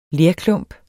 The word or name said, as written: lerklump